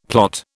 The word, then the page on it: plot: British English